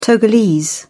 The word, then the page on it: togolese: British English